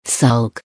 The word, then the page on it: sulk: American English